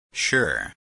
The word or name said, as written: sure